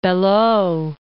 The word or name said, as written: Belleau